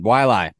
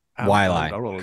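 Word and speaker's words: Waialae: What we got going on? Wailai. I think that's how you pronounce it. Wailai. Country Club. – Wailai.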